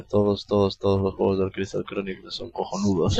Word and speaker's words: Chronicles: No, es que lo que es decir Chronicles. – ¿Cómo se dice? – Chronicles. – Muy bien.